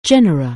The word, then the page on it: genus: anglais